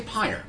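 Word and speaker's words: Pyr: I think they say Pyre. – They say Pyre. – Yeah. – They're lacking an E if they want to be Pyre. They should be P-Y-R-E.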